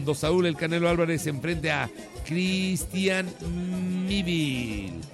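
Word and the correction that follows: Mbilli: Se pronuncia Mbilin. Ah, sí, cierto. Mbilin.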